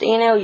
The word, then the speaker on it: Inu: It's pronounced Inu.